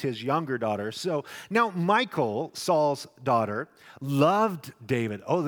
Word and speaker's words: Michal: She's been called Michal, if you will, but literally the Hebrew is Michal. That's the way you say her name, Michal.